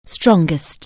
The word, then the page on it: strong: anglais